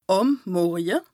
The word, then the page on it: àm Morje: Bas-Rhin; Strasbourg